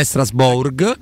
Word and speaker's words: Strasbourg: Che la G non si pronuncia la G finale, quindi non è Strasbourg, non è Strasbo, è Strasburgo.